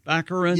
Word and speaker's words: Baccarin: Baccarin? – Baccarin.